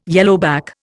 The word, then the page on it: yellowback: Indian